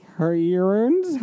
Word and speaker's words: heroines: And then let's see here, at 1:30 to 2, we have Super Heroines. How do you say that? H-E-R-O-I-N-E-S. – Heroines. – Heroines, thank you.